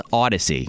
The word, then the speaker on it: Audacy: We're now part of a new brand called Audacy. Mm-hmm. Like audio. A-U-D-A-C-Y is how it's spelled. Audacy.